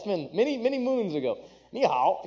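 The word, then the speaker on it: nǐ hǎo: I would say, nanhai, right? Am I saying that right? No. Nihao, thank you.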